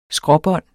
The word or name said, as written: skråbånd